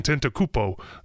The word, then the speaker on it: Antetokounmpo: You have to get an Antetokounmpo. And I butchered his name, and I always will. – That's pretty good.